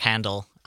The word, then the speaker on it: Handel: The classical composer George, I don't know how you actually pronounce his name, George Frederick Handel, something like that. – Yeah, you got it, sure.